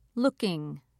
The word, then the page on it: looking: American English